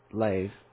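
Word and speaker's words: lev: When he says that at the beginning, that they do not consider in their hearts, okay, the heart in the Old Testament, the lave, L-E-V, L-E-B really, but it's pronounced lave, okay.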